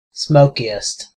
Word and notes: smokiest: American English

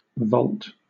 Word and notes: volt: British English

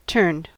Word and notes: turned: American English

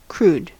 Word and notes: crude: American English

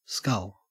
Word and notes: scull: Australian English

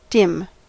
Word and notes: dim: American English

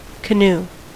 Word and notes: canoe: American English

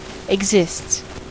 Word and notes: exists: American English

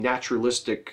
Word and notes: naturalistic: American English

